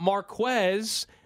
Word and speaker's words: Marquez: And we're, of course, talking about Marquez Valdez, who gets his full name pronounced, by the way, when he's making catches. – Yeah, not MVS, Marquez.